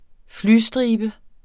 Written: flystribe

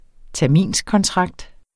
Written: terminskontrakt

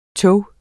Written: tog

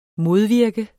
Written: modvirke

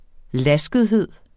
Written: laskethed

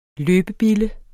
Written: løbebille

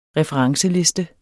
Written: referenceliste

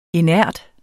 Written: inert

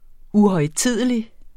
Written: uhøjtidelig

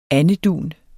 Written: andedun

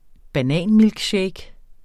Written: bananmilkshake

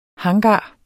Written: hangar

